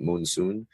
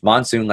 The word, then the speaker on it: monsoon: They pronounce it moonsoon. It's monsoon, like the weather pattern.